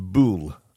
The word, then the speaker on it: Bulwell: She said, it's a small town called Bulwell. That's how it's spelled, all right, Bulwell. She said, but it's pronounced Bool by us locals.